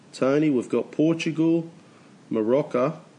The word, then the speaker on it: Morocco: Iran, not Iran, and Morocco, not Morocco. Morocco is like a drink.